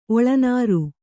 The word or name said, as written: ಒಳನಾರು